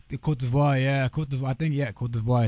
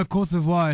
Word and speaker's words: Côte d'Ivoire: Cote d'Ivoire is how you say it. Yeah, in French.